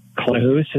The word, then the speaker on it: Klahoose: Am I saying that properly, Klahoose? – Klahoose is the correct name.